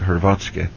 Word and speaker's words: Hrvatska: You know, Nikola Tesla was born in an area that today we call Croatia, Hrvatska. That's how they say it, Hrvatska.